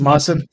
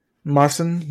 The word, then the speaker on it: Mawson: I don't know how to pronounce Mawson. It. – It's Mawson. – Mawson, like, that's a player that has a Worlds experience right there.